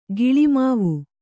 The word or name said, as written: ಗುಲಿಮಾವು